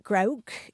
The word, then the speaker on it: groak: It's spelled either G-R-O-A-K or G-R-O-W-K. And it's a Scots term. And if you look it up in the Dictionary of Scots Language, it says that grouk is to